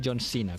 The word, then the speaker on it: John Cena: se llama John Cena, es como se escribe, pero es John Cena, como se dice.